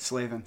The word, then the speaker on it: Slavin: Slavin.